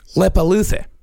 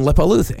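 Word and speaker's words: Leppalúði: These I know I'm actually pronouncing right. – Okay. – There is a Grilla and Lepaluthe. – Huh. – Yeah. Grilla and Lepaluthe.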